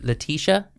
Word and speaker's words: Letitia: Is her name pronounced Letitia? – Letitia? Mm-hmm. – Yeah.